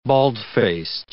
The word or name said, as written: bald-faced